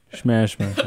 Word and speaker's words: Smashmere: How do you say it? Shmashmer. – It's like Casper. Shmashmer.